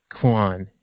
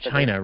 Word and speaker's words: Qin: So in essence, you know, the material world, that he had decided not to kill the king of Quan How do you say the name of the region? Is it Quan or Quinn or something like that? – I think it's like Chin or, yeah, something like that.